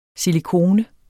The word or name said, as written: silikone